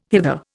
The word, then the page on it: hither: Indian